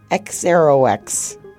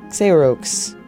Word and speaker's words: Xerox: X-Aero-X. X-Rox. Xerox. Xerox.